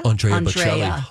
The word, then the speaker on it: Bocelli: Now we've got Katy Perry and how do you say his name? Bocelli? – Andrea Bocelli.